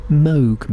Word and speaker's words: Moog: Well, that was Swansiver, an artiste from Shropshire, and that was a track called Moog Meadows, or if you're a stickler for the correct pronunciation, Moog Meadows.